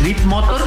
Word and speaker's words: Leap Motor: Leap Motor, que se escribe L-E-A-P. – Como brinco en inglés, Leap Motor.